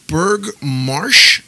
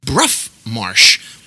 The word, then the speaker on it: Brough: It's called Berg Marsh. And that's how we say it. But I looked at the pronunciation, and it's called Brough Marsh.